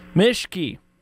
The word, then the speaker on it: Mischke: Mischke. – Spell it. – M-I-S. – M-I-S. – C-H-K-E.